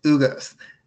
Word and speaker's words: Ugas: Um, Eugenius Ugas. – Is Eugenius Ugas.